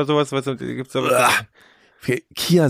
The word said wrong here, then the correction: Chia: Chia, wie, wie spricht man das eigentlich wirklich Chia, aus? Chia, hier, Chia.